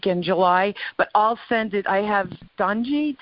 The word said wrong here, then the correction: Donja: Did I say her name right? – Danya. Yeah, D-O-N-J-A. Yep.